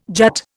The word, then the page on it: jut: Indian